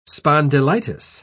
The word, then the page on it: spondylitis: British English